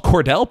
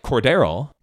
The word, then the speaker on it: Cordero: Name's not Cordell. – Name isn't spelled like Cordell either. – Not at all. – It's very odd. It's spelled like Cordero. So many people pronounce it Cordell.